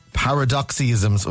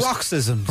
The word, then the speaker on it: paroxysms: Irish Twitter users went into one of their periodical paradoxisms. Oh, am I even saying that right? – Paroxysms.